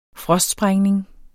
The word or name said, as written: frostsprængning